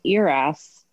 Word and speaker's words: ERAS: So then my doctor said, well, you might be a great candidate for this new protocol that we're doing with C-sections called ERS. And I tell people it's pronounced ear, like ear is in the thing attached to your head, and then ass is like the thing that is your tush.